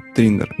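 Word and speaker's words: 3inder: Э, называется Триндер. Слышал? Триндер? Да. – Нет. – Э, пишется э, три как цифра, а потом эм, I-N-D-E-R. Ну, как ну, Триндер. Ну, по сути, как Тиндер, только Триндер.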